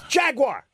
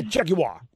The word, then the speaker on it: Jaguar: Like when people say Jaguar. It's Jaguar. Okay, not Jaguar. – No, but in the commercial, he says Jaguar. – Jaguar. – Jaguar. – Jaguar. It's like Jaguar.